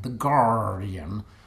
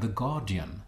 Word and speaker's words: The Guardian: Varför ska den uttalas med amerikansk engelska mening? Alltså det rimliga är väl att säga The Guardian. Alltså utan hörbart R, eftersom så uttalar man namnet där tidningen ges ut.